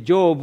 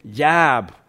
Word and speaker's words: Job: he was not pronouncing it Job, which was kind of my English-British accent. He was saying Jab.